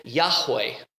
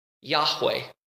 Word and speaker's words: Yahweh: So some people believe that you pronounce it Yahweh. Yahweh.